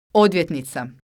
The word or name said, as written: odvjetnica